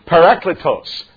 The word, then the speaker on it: parakletos: And the phonetic pronunciation of the Greek word is parakletos.